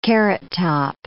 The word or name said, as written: carrot top